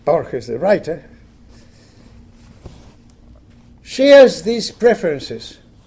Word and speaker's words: Borges: I don't know if any of you have heard of a world-famous Argentinian writer, 20th century Argentinian writer, called Jorge Luis Borges, actually spelled B-O-R-G-E-S, and sometimes it's pronounced Borges in English, but it's really in Spanish is Borges.